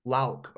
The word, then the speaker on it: Louck: Louck.